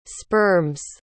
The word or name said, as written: sperms